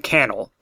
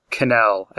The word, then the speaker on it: Cannell: I know now that his name is pronounced Stephen Cannell, not Cannell, as I've been saying it.